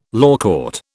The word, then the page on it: lawcourt: British English